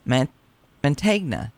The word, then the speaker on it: Mantegna: including Joe Mantegna.